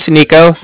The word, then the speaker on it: Niko: My name's Nico. That's spelled N-I-K-O.